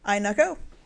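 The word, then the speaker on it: Ayenako: Inukko. Not spelled phonetically at all. – Unless it's A-Y-E-N-A-K-O.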